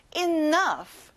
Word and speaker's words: enough: 그 다음, 충분한, enough. 끝에 GH는 F 발음을 내는 거예요. enough. Very good, everyone.